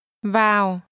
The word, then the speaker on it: vào: Vào. – This word is pronounced with a falling tone.